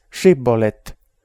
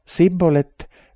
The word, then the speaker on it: Shibbolet: Ja kui ta vastas ei, ütlesid nad temale, ütle siis Shibbolet. Ütles ta aga Sibbolet, seepärast, et ta ei osanud õigesti hääldada, võtsid nad ta kinni ja tapsid Jordani koolmete juures.